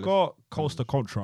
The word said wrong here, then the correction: Coast Contra: I think that's how you pronounce their name. – Coast Contra.